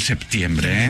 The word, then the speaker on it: septiembre: Muy bien pronunciado el septiembre, ¿eh? – Con P, sí, nada de decir nada, septiembre.